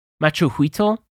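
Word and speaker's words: macuahuitl: Like, I can never get over the Mesoamerican Machuhuitl, I believe it's pronounced.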